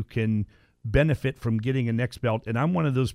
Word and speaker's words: NexBelt: Next Belt is spelled N-E-X-B-E-L-T. There's no T after the T. It's not Next Belt. It's Next Belt.